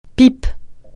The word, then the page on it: pipe: francais